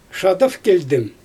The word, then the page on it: ʃadav kelʲdɪm: Xyzyl